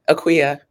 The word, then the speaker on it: Akua: Akua. – Akua. – It's not spelled the way it's pronounced, but that's how the Ghanaians say it.